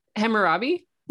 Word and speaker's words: Hammurabi: And they mentioned, I don't know how to say this, Hammurabi's code? – Hammurabi? – Yeah, thank you.